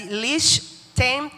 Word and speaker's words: Liechtenstein: de cómo es que se llama de pronunciar y se dice así, Liechtenstein.